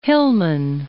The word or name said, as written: Hillman